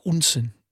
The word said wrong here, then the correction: Odense: Odense, that's crazy. – Odense, yeah. – It's like a dance music. That's how I remember how to say it.